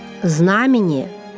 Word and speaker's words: знамение: Хочу обратить ваше внимание, милые наши слушатели, на правильное произношение следующих слов. Запоминаем. Издревле – иконопись, знамение, вероисповедание, духовник. Наша передача «Уроки словесности» подошла к концу.